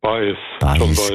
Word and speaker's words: Baez: Joan Baez oder Baez? – Baez, gell? Ja. – So. – Latinisch ausgesprochen.